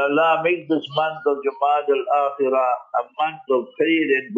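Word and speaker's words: Jumada al-Akhirah: Hence, tonight will usher in the month of Jumad al-Akhirah. So lesson number one, know how to pronounce the month correctly. So tonight will be Jumad al-Akhirah.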